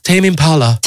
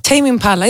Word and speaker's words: Τέιμι Μπάλα: Και ευτυχώς που σε πήραν και τηλέφωνο να μας πεις πώς προφέρεται και το όνομά του. – Τέιμι Μπάλα. – Τέιμι Μπάλα. Είδες, ναι.